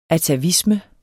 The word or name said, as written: ATAVISME